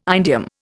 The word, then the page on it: indium: Indian